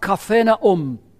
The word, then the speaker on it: Capernaum: In fact, it's pronounced Capernaum in Hebrew.